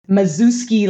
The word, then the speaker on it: Maszewski: I'll take the American pronunciation. Mazuski, like a zoo. Mazuski, yeah. You know what? Maz- Méami Mazuski, not married last name.